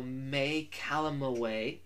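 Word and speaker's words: May Calamawy: We also saw May Calamaway, I actually pronounced that right, as Mark Spector's wife, or soon-to-be ex-wife, Layla L.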